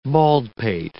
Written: baldpate

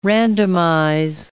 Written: randomize

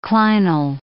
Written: clinal

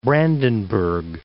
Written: Brandenburg

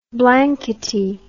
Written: blankety

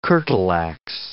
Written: curtle ax

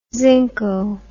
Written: zinco

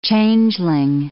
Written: changeling

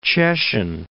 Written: Cieszyn

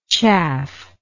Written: chaffingly

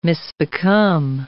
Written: misbecome

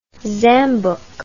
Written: zambuck

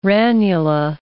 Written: ranula

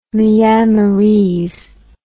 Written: myanmarese

